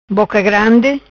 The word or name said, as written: Boca Grandi